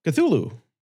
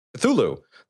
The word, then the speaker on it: Cthulhu: Cthulhu. – Cthulhu.